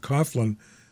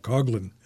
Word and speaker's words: Coughlin: And she's married to a guy that's really famous, Jack C-O-U-G-H-L-I-N. I always pronounced it Coughlin. But he pronounces it Jack Coughlin, or Coughlin,